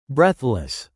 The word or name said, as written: breathless